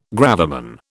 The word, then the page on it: gravamen: British English